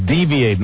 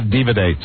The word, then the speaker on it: deviate: The word is deviate, not divinate, sir. Deviate. You didn't pronounce the word correctly.